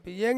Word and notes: yeng: Ansus

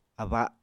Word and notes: aba: Apatani

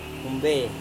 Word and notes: humbe: Serewen

